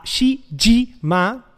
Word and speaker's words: Shijima: Hoy vamos a estar hablando de The Centennial Case, el Shishima, a ah, Shijima, que mi sensei no me escuche pronunciando mal esas palabras.